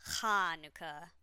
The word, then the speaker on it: Hanukkah: You can also spell it Hanukkah with an H and 2 Ks, but I like doing the one that forces you to pronounce the first syllable correctly, like you're clearing your throat. Hanukkah, with extra emphasis on the ha.